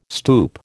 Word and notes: stoup: American English

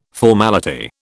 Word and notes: formality: British English

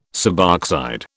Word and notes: suboxide: British English

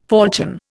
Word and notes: falchion: Indian

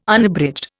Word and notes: Unabridged: Indian

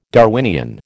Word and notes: Darwinian: American English

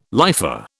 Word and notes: lifer: British English

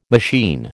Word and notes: machine: American English

